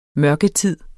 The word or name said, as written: mørketid